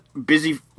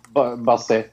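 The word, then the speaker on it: Busy: It's got, Boozy Phillips? How do you say her name? – Yeah, Bussy.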